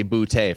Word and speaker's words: Boutte: Well, first of all, Cody, I think people need to understand that is definitively how you pronounce his last name. It looks like it looks like Butte, but it is actually Boutte.